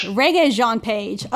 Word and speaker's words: Regé-Jean Page: Regé-Jean Page.